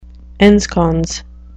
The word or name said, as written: ensconce